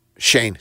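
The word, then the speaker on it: Schoen: as well as general manager Joe Skeen. How do we say it? – Shane.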